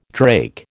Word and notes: drake: American English

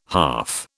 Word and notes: half: British English

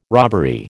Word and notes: robbery: American English

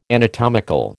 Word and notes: anatomical: American English

